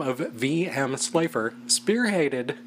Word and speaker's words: Slipher: Slipher. – Spell it. – S-L-I-P-H-E-R.